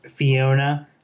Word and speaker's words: Pheeyownah: That's Fiona, spelled P-H-E-E-Y-O-W-N-A-H, Fiona, like phonetically. So just so you know, if you're looking for it. – Do not spell it F-I-O-N-A.